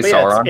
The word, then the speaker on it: Sauron: I feel like I'm mispronouncing that, but... – No, I think people say Sauron.